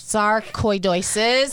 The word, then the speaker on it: sarcoidosis: Sarcoidosis. – Sarcoidosis.